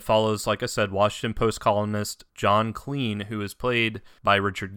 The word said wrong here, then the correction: Klein: I think it's actually John Klein. Klein, Klein.